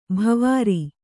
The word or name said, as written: ಭವಾರಿ